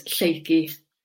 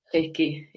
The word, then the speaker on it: Flakey: So it's Flakey. – Flakey. – Flakey, yeah.